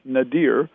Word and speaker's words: nadir: Well, this sets in motion what we call the Nader. Some people pronounce it Nadir, N-A-D-I-R. We're not talking Ralph.